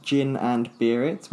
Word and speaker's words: Gin and Beer It: So it's gin, like the drink, gin and tonic, and beer, like the drink.